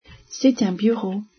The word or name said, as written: bureau